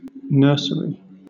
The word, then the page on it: nursery: British English